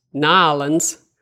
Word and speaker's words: New Orleans: It was the United States of America, the city of New Orleans, which the natives pronounce Nolens.